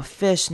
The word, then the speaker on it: fish: 那 star 呢，r 是念成 r 的声音，然后 fish 呢，注意短母音 i fish，很多人会念成 fish，那那样就是念成长母音 e 的声音了。